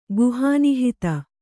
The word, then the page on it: ಗುಹಾನಿಹಿತ: guhā nihita